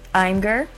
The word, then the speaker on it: Imgur: So that's spelled I-M-G-U-R, then M-E-M-E-G-E-N. When it comes to viewing memes, entire communities exist. Imgur, or Imgur, however you prefer to pronounce it, then there's Reddit, and Tumblr, so those three are among the most popular.